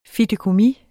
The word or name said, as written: fideikommis